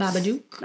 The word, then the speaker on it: Babadook: Did I say it wrong? Babadook? – No, you did. Whatever. – It's Babadook.